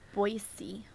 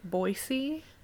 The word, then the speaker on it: Boise: Boise. – They say Boise, not Boise. – Boise. Italian. Get the Italian. – French, Boise. Actually. – Oh, French. – Yeah. – Boise.